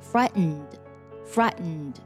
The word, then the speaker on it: frightened: 那么在美式发音当中呢，通常会把这个 t 进行鼻音爆破，读作 frightened。